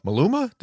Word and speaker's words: Maluma: because otherwise otherwise Maluma, did I say it right? – Yeah, correct.